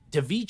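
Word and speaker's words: Daveigh: And this, of course, I didn't know, but this is Devi Chase, I think her name is pronounced, also known as fucking Samantha Darko, Donnie's younger sister, who actually got that movie to herself, S.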